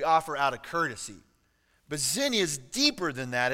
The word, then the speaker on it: xenia: So the Greek word for hospitality is actually, it's a X-E-N-I-A. It's pronounced xenia.